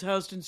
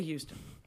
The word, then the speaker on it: Houston: It's a sort of a cowboy because it's Houston Street in New York. But it's spelled like Houston. – Sure. – Why wouldn't you say Houston? – But we bought, for the bit, jeweled t-shirts.